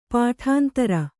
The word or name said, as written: ಪಾಠಾಂತರ